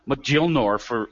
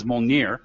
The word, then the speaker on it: Mjolnir: I thought it was Mjolnir forever until I realized the pronunciation was Mjolnir because,